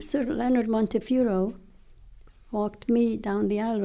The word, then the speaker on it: Montefiore: And there's also, what's the name of Mr. Montefiore? Am I saying that right? – Montefiore.